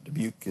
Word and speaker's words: Dubuque: but Dubuque is how you pronounce it.